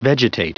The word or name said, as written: vegetate